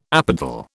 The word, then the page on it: apodal: British English